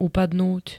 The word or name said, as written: upadnúť